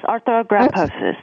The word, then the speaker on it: arthrogryposis: So, Jennifer, you told me that you have a family history of, now how do you pronounce it, arthrogryposis? – Yes, arthrogryposis, that's correct.